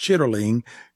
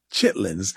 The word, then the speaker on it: chitterling: It was part of what they called the, even though the word is more accurately pronounced, chitterling, we say Chitlins.